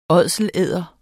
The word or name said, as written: ådselæder